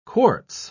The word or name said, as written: quartz